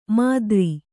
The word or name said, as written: ಮಾತರಿ